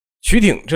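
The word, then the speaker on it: 句町: 首先咱们得说一下这两个字的读音啊。曲挺这两个字呢，就是句子的句，听呢就是田字旁一个甲乙丙丁的丁啊，也念挺也念丁。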